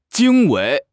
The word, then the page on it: 经纬: HSK6